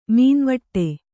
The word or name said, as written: ಮೌನವಡು